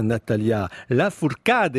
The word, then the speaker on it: Natalia Lafourcade: Natalia Lafourcade, il faut le prononcer comme ça, est une chanteuse qui nous vient du Mexique.